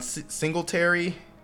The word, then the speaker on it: Singletary: Grant Hill, Robin Lopez, Steve Nash, Shaq, Jason Richardson, Courtney Sims, Sean Singletary.